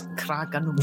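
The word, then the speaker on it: Craig-y-Nos: Craigenors. Is that right? – Yes. – Craigenors. – That's even better than a local.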